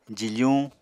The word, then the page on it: jifuñ: apatani